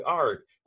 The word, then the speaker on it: Arik: Now, do you pronounce it Arik or Eric? – Arik. – See, I be knowing shit. I talked to my homie. I was like, yo, I gotta interview the homie Arik. And he was like, how you spell it? It was A-R-I-K. Like, how you think that shit sound? And then he's like, oh, that's not Eric. I'm like, bruh, air is A-I-R.